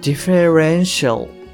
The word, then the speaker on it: differential: ディフェレンシャルではないと思うんですね。このディフェレンシャルの発音っていうのは、あの、 RE のところにアクセントがあるんですけれども、まあそういった話し方をしていないっていうので、ちょっと違うかなと思います。